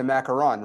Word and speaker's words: macaron: No, the way you said it, I'm like, does she show up and then, is that now, would you call that a macaron or a macaroon? Yes, you said it right. – It's a macaron, right? – Yes. – Because macaroons are the other things with. – Those are the ones with like coconut.